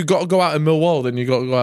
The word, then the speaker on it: Millwall: Millwall. Say again. – Millwall. – Now you're saying it right.